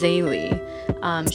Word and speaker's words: XayLi: Everybody keeps asking me how to pronounce her name. It's XayLi.